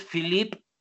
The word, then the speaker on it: Philippe: El compilador es Philippe, como Felipe en francés, PH, Philippe Aries como el signo zodiacal, pero con acento en la E.